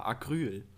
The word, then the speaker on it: Acryl: Aber dann eher auf Betonung auf A, Acryl.